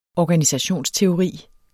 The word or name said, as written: organisationsteori